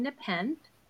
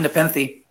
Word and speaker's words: Nepenthe: And he is the author of the novel Kind Nepenthe. Did I say it right? – No, it's Kind Nepenthe.